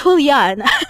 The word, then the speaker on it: Julian: Am I saying Julian all this time, or am I saying Julian? It's Julian. I'm sorry. It's spelled as Julian, but it's Julian. It's pronounced as Julian.